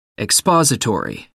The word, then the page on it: expository: American English